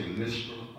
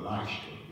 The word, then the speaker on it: Lystra: And I might say Lystra or Lystra. It's pronounced both ways.